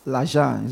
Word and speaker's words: l'argent: And I mean money, l'argent. Is that how they say it in French? – L'argent. L'argent. Yeah.